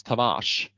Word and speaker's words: Tavares: Ska det vara på portugisiska då ska det uttalas Tavares.